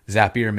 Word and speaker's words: Zapier: Zapier makes you happier. That's the pronunciation key.